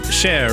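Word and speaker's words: Cher: Jag ger dig share. Believe. Jag måste bara notera musikläggarnas notis här om share uttalas som i engelska shine, rimmar på bear, inte beer.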